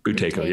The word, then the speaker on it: Buteyko: Buteyko.